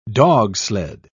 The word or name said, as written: dogsled